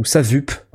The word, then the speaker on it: Sawup: Et donc il fait il est le patron d'une plateforme de formation euh, dont j'ai peut-être un peu parlé déjà, qui s'appelle SOUP, s a w u p euh, ou SAVUP, si vous la prononcez euh, américaine. SAVUP.